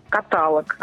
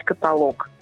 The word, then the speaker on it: каталог: Очень распространенная ошибка – каталог, а нужно говорить каталог.